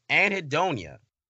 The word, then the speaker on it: anhedonia: Anhedonia. – I'm so impressed you pronounced that right.